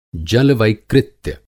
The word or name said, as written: जलवैकृत्य